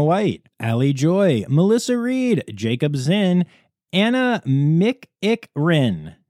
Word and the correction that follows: McEchran: Okay, so it's spelled differently, and she sent me a pronunciation, and it's McEchran, obviously, but she explained it as Mick-Eck-Rin, and we like to have fun in this section.